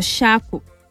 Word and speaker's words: Eshaku: So the three main bows, we have the shaku. Shaku, but it's spelled E-S-H-A-K-U.